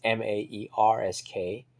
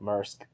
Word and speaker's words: Maersk: And again, I don't know how to pronounce that. It's Maersk. I think that's how I've heard it pronounced, I think. It's a Danish company.